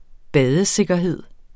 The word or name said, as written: badesikkerhed